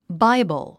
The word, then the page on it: Bible: báibl; バイボゥ